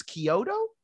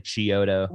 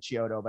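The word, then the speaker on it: Chiodo: You're one of the, I guess it's Kyoto is how you pronounce that. – Yeah. Kyoto. Some people say Chioto. – Before I met him, I said Chioto, but I think he corrected me. – Yeah. I think it's Kyoto.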